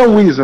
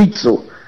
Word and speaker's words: Wizo: Não é Wizo, na verdade, a pronúncia é Witzo. Mas se escreve Wizo, W-I-Z-O.